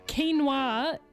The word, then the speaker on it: quinoa: And also thanks to the person who said quinoa is pronounced quinoa, apparently. My Brazilian stepmother, who speaks five languages, would get super mad and argue with me whenever I pronounced it quinoa.